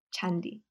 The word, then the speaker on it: Jandi: It's like candy with an H. – No, it's spelled J-A-N-D-I. Chandi.